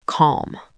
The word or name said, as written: calm